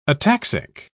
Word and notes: ataxic: British English

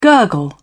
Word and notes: gurgle: British English

